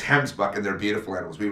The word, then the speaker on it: Gemsbok: They didn't have them on the concessions, but he said in the next 2 years they plan on having them, so a Gimsbok definitely on the list. – All right, can I correct you for a minute? It's Gemsbok. Okay. You got to say it right if you're going to hunt it out of South Africa.